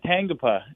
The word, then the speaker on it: Tangipa: Yeah, they said Tangipa, and I was like, wow. You know, they're Polynesian, and it's Tangipa. – Tangipa. Oh, my God. So, I was saying it wrong the whole time.